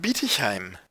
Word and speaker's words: Bietigheim: In das Abenteuerland Bietigheim. Ah, ja, äh, Betonung auf äh, bieten im Sinne von äh, schlagen.